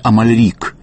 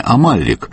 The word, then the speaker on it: Амальрик: Как же правильно? Амалик или Амалик? Сам Андрей Алексеевич говорил, что корень его фамилии арабский. Амаль значит труд, но предки его жили во Франции, и ударение на последний слог стало в семье привычным. Тем не менее, сам Андрей Алексеевич не протестовал и против ударения Амалик, так что я предлагаю произносить его фамилию так, как каждому фонетически удобно.